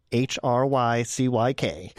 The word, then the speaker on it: Hrycyk: and then H R Y C Y K as my last name, Hrycyk.